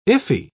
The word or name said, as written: iffy